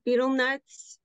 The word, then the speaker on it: betel nut: He's been doing research on people in the South Pacific who chew betel nuts.